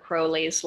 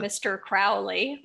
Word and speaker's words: Crowley: And first of all, thank you for pronouncing Aleister Crowley's last name correctly. You're one of the few people, because I think Ozzy ruined it for everybody with his song, yeah. Mr. Crowley, but it's really right, Crowley.